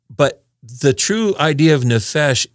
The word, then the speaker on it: nefesh: It's called nefesh in the Hebrew is how you would pronounce it.